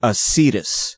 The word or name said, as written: acetous